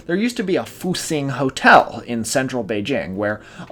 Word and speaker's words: Fuxing: That's pronounced Fuxing, and it means rejuvenation, roughly.